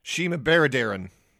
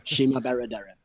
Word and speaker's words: Shima Baradaran: I think I need to correct the pronunciation to American. Here it would be pronounced Shima Baradaran. – Oh, okay. – Yeah. – I apologize. So you know. Shima Baradaran.